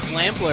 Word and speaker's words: Slampler: What's that? Slampler? – The Slampler. – Am I saying it right? – Yeah.